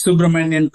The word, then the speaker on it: Subramanian: Yeah, it's Subramanian Kunchithapatham.